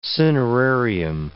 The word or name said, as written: cinerarium